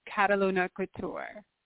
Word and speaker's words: Kataluna Couture: Yes, it's Kataluna Couture.